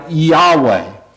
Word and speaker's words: Yahweh: And so that's the best guess of how it is supposed to be pronounced. When Moses is told that I am sent you, what God is saying is something like Yahweh.